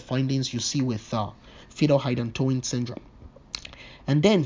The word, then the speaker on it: hydantoin: See how hydantoin rhymes with phenytoin.